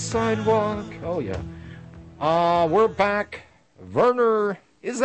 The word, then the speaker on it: Werner: Werner. The W is pronounced like a V in English.